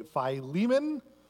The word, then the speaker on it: Philemon: Let me ask you to turn with me to the book of Philemon this morning, or as some say, Philemon. We had an argument in the car on the way over from church how to pronounce Philemon.